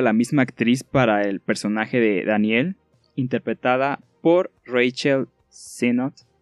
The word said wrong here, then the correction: Rachel Sennett: les debo esa pronunciación, que hace un excelente trabajo actoral. Rachel Sennett. Ojo ahí. Rachel Sennett.